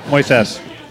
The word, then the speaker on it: Moises: Accent on the ses. Moises.